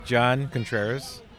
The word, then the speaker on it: John Contreras: Yes, John Contreras. – Could you spell that for me? – Yeah, J-O-H-N, last name C-O-N-T-R-E-R-A-S.